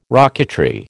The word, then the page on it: rocketry: American English